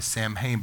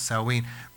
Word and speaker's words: Samhain: Samhain, it's in English, it's spelled S-A-M-H-I-N, I believe. But we would say probably Samhain, but it's pronounced Samhain.